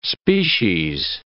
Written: species

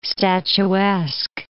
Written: statuesque

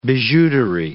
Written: bijouterie

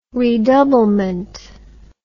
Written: redoublement

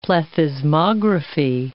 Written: plethysmography

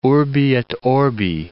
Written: urbi et orbi

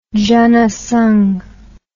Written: janasangh

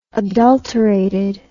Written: adulterated

